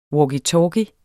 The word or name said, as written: walkie-talkie